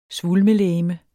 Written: svulmelegeme

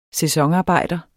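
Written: SÆSONARBEJDER

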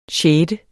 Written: sjette

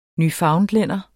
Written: newfoundlænder

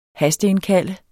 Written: hasteindkalde

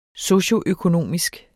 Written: socioøkonomisk

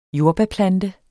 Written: jordbærplante

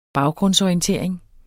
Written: baggrundsorientering

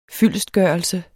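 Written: FYLDESTGØRELSEN

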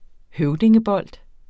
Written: høvdingebold

